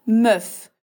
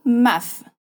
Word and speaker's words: meuf: Sendo assim, uma palavra como femme, mulher, que o primeiro E se pronuncia como A, se torna meuf, com som de E e não maf.